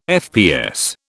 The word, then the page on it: FPS: British English